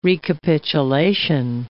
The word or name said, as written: recapitulation